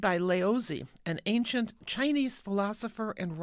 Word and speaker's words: Laozi: It's interesting about his name, Laozi, L-A-O-Z-I. That is, by the way, the present-day Romanized pronunciation.